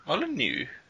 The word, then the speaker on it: Molyneux: There is a new, I don't know the name of this guy, how to pronounce it, Almighty Molyneux? – Molyneux.